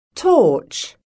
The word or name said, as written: torch